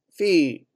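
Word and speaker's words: Fi: Topping the list, Fee, once again that's spelled F-I, a memoir by Alexandra Fuller.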